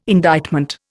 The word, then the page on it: indictment: Indian